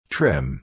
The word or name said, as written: trim